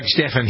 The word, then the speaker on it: Stephan: I'm Doug Stephan here on the Good Day program. S-T-E-P-H-A-N. Some people spell it J-S-T-E-V-E-N, like it was Stephen.